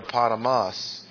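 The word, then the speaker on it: potamos (ποταμός): If you're following in a Greek Testament, you notice it's the word potamos. Accent on the last syllable.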